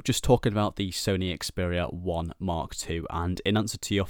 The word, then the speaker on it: Xperia 1 II: Apparently it's pronounced Xperia 1 Mark II, but it's just very confusing and just doesn't really create much clarity in terms of the naming.